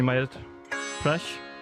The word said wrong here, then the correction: Madhya Pradesh: Fantastiske øh, Madhya Pradesh. – Madhya Pradesh. Så er det sådan, man udtaler det? – Is it uh, Madhya Pradesh? – Yeah, Madhya Pradesh is the central state of India. Uh, so uh, you are very right.